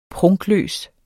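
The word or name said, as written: PRUNKLØST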